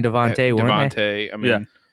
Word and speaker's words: DeVonta: It's spelled Devonta, but all the announcers were saying Devontae. – They said he's actually, when he was growing up, he was actually called like just Tay. So I think it's pronounced Devontae.